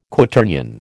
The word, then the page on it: Quaternion: American English